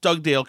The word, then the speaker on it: Dugdale: Okay, first of all, did I pronounce Dugdale correctly, Anthony? – You did. It was perfect.